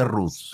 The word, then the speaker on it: Rote: Como talleres Rote, se escribe Rote, se pronuncia Ruth.